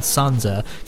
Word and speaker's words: sanza: But there is mizmar, the nai flute or ney flute, and sanza.